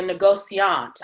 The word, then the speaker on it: négociant: So you go big as a négociant. I said it right that time. – Yes, yes, you got it right, yes.